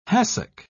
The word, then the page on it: hassock: British English